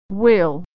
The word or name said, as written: we'll